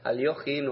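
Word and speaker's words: Alekhine: Yo, claro, yo escribo Alequín porque él escribía así, esa, eh, aunque pronunciado debería ser aproximadamente, si mi ruso no es tampoco Aliojin o Aliojin, algo así.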